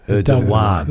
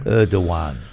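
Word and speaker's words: Erdoğan: Erdogan. Like Recep. That's his first name. Richard. Recep. – Yeah. – Erdogan.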